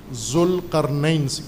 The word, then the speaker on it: ذو القرنین: وَيَسْأَلُونَكَ عَنْ ذِي الْقَرْنَيْنِ، اے رسول آپ سے سوال کرتے ہیں ذو القرنین کے بارے میں. ذو القرنین، صحیح تلفظ، بعض ذو القرنین کہتے ہیں، بعض ذو القرنین کہتے ہیں. ذو قرنین، قر قاف کے اوپر زبر ہے ہمیشہ،